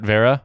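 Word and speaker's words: Wera: Like the screwdriver manufacturer, Vera. W-E-R-A. I pronounce it Vera.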